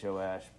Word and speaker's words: Joash: How do you say that? Joash? Yeah.